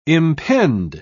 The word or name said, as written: impend